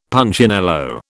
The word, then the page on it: Punchinello: British English